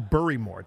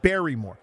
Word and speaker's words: Barrymore: B-E-R-R-Y. Right? – Yeah. – Drew Barrymore. It's not Drew Burrymore. It's Barrymore. So if you want to be a berry, get your E in there.